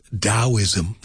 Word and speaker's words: Taoism: which technically is supposed to be pronounced Taoism. It's written with a T or written with a D.